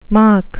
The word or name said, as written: mach